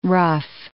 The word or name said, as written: ruff